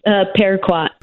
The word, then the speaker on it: Paraquat: Paraquat.